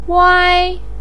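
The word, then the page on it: wai: tone 1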